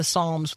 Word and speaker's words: Psalms: Let's spend some time in the Psalms with the silent P.